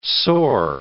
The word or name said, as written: sore